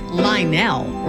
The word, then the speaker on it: Lionel: fact, it's not three syllables, Lionel. It's two syllables, Lionel Richie.